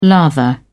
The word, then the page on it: lather: British English